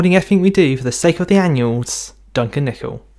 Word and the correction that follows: annals: That's a really good intro, but I did notice that you pronounced it as an annual, like a collection of Beano magazines. It's the annals.